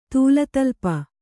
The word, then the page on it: ತೂಲತಲ್ಪ: Kannada